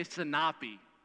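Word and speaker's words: sinapi (σίναπι): Let me hear you say sinapi. Oh, you can do better than that. Now you're like, sinapi. There you go. Yes, the second syllable, sinapi.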